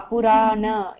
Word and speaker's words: Purana: Puruna. – No, no, it is Ra, Purana. You said right? Purana, Purusha.